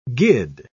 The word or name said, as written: gid